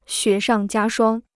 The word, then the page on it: 雪上加霜: xuě shàng jiā shuāng